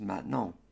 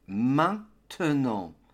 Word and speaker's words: maintenant: The academic pronunciation of maintenant includes the TE in the middle of the word. Hence we get maintenant, maintenant. Mais en général ou très souvent, les Français ne disent pas le te. Alors le mot devient maintenant, maintenant.